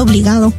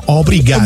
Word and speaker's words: obrigado: Porque van a pensar que estás diciendo obligado. – No, obrigado.